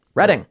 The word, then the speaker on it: Reading: The PA town is pronounced like the color red. Reading. – Reading, yeah.